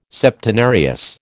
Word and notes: Septenarius: American English

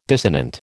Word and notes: dissonant: American English

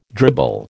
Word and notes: dribble: American English

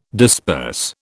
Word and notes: disperse: British English

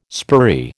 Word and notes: spurry: American English

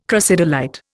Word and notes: crocidolite: Indian

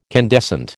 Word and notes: candescent: American English

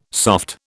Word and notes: soft: British English

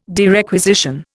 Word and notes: derequisition: Indian

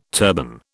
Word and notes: turban: British English

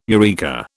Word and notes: eureka: British English

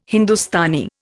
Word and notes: Hindustani: Indian

